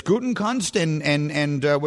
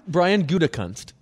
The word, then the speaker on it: Gutekunst: There's Gutekunst, which is how I'm told now to say his name, right? I've been saying it wrong all this time. – Brian Gutekunst. – It's not Gutekunst. I've never gotten it right.